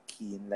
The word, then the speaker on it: Keen: You guys can call me Keen, like the adjective. So, it's spelled K-E-E-N.